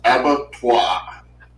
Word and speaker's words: abattoir: In the chat says abattoir. – Abattoir. – Yeah, that's how you say it.